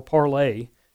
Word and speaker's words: Parler: And for those out there that have decided to try to do social media on Parler or Parlay, as many people pronounce it, we Americans tend to pronounce it how we think it should be pronounced.